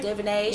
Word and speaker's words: DuVernay: Ava DuVernay. – DuVernay.